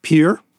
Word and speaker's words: Peere: And this family, I don't know quite how you pronounce this, but the family name is spelled P-E-E-R-E. So it's like Pier.